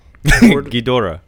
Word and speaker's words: Ghidorah: How do you say its name? Gondor? – Ghidorah. – Ghidorah.